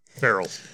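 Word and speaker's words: Ferrell: And then Conchata Farrell. Feral.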